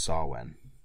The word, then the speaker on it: Samhain: But yeah, it's pronounced Samhain.